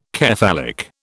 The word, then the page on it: cephalic: British English